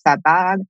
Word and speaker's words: Sabbagh: It's hard when you read and you never hear it pronounced, but Sabah, S-A-B-B-A-G-H, Sabag.